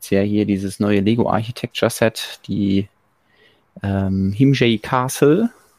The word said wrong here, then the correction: Himeji: Nee, bestimmt, nee, nicht Himjay. Himeji Castle.